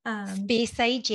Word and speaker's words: Speyside: Speyside, yeah.